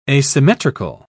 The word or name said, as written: asymmetrical